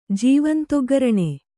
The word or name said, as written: ಜೀವಂತಒಗ್ಗರಣೆ